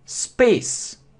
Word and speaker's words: space: Space, космос. Не читаем, это немая И.